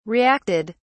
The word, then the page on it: reacted: British English